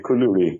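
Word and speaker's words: koulouri: kouloury. – Exactly, kouloury.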